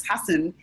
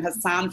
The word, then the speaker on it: Hasan: I do warm up for the Patriot Act when we were able to tape, and Hasan Minhaj, his name is Hasan, and people were calling him Hassan for the longest.